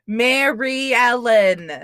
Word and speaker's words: Mary Ellen: Because he fucking calls her Mary Ella. – Ella. – It's Ellen. Mary Ellen. – Like DeGeneres.